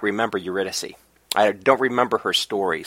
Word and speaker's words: Eurydice: And then we'll be doing the first two of a three-part arc called Eurydice. – Eurydice.